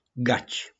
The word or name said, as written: GACI